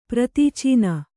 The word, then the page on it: ಪ್ರತೀಚೀನ: kannada; pratīcīna